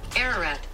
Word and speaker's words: Ararat: Ararat.